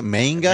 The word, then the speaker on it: manga: Tomorrow, this weekend, Wizard World has everything, movies, comics, toys, video, gaming, games, TV, anime, manga. Is that how you say it? Manga? – Manga.